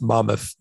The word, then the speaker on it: Monmouth: because i'm from new jersey it's pronounced monmouth it's monmouth college i,